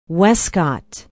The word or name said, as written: wescott